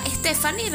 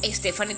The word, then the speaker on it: Stefany: arroba Torrealba Stephanie. Recuerden que mi nombre se escribe S-T-E-F-A-N-Y. Me encuentro también en Facebook como Stephanie Torrealba.